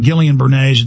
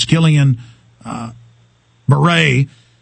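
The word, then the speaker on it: Guillain-Barré: It's not Gillian Bernays, it's Gillian Barre.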